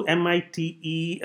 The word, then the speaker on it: MITE: M-I-T-E, but we pronounce it MITE.